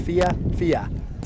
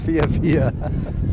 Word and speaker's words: FIFA: It is pronounced FIFA. Two syllables, FIFA.